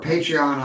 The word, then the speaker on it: Patreon: Yep, and the IB, definitely look at the Patron membership for our Patreon. I always say that wrong. Patreon, or you can see RC going, learn how to talk, you damn country redneck.